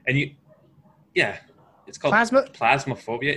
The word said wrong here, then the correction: Phasmophobia: It's PH. Phasmophobia, maybe? – Yeah, it means like fear of ghosts.